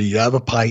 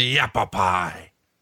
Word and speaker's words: Yavapai: But, you know, who else other than a nerd like me who loved to read about history would know what a Yavapai Indian strap match would be? – No, who came up with that?